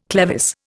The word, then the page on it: clevis: Indian